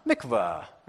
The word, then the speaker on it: Mikvah: Mikvah. Right? You got some Hebrew in today. You got to like say it like really Hebrew though. Mikvah.